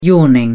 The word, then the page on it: yawning: British English